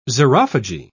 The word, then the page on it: xerophagy: British English